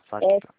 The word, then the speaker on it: FACTA: É, FACTA se escreve FACTA. É com o C mudo. FACTA.